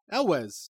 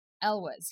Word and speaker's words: Elwes: Like, Carrie, I don't know how to say his last name, Elwes? – Oh, Carrie Elwes. Yeah, Elwes. – Elwes.